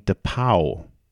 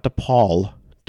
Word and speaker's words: DePauw: Wisconsin White Lacrosse will now take on North Central out of Illinois, and Whitewater will be playing DePauw, not DePaul, DePauw. It's spelled the same except for you take an L and turn it into a W.